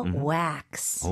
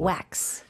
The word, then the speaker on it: wax: 영어로는 이름절로 발음하셔야 돼요. Wax. – 오. – Wax. – 지금 들으니까 너무 와도 아니고 너무 외도 아니고 그 중간으로 이어지는 느낌입니다.